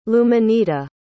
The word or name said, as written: Luminita